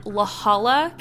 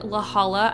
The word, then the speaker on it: La Jolla: So this story takes place in La Jolla, California. I think that's how you say it. It's L-A-J-O-L-L-A.